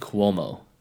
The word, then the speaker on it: Cuomo: Definitely Cuomo.